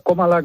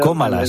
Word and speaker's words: Kamala: Bueno, Kamala, o Komala, como se debe pronunciar. – Komala, se debe pronunciar, como es Drújula.